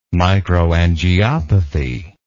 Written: microangiopathy

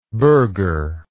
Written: burghership

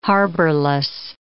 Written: harborless

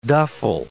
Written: duffel

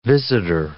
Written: visitor

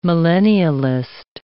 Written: millennialist

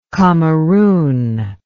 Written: kamerun

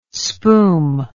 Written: spoom

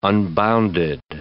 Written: unbounded

